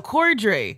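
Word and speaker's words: Corddry: It's Rob Coldry. Wait, did I say your... No, Cordry. – Cordry, yeah, you said it right.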